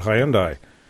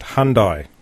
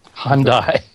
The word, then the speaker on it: Hyundai: And I think the most interesting thing about Hyundai in the US is that it's not Hyundai. It's pronounced Hyundai. – Hyundai. – The Y is silent, essentially. They say Hyundai. Hyundai is basically what they say.